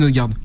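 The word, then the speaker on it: Ødegaard: Il y a lui, le numéro 10 d'Arsenal, Edegger, c'est ça, si j'ai pas écorché son nom. – Martin Odegaard, ouais.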